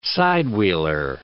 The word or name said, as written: side-wheeler